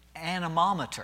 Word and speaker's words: anemometer: You know, we can measure the physical wind as it blows by using an instrument called the, I'm going to say it right, anemometer. If I can get all my syllables correctly.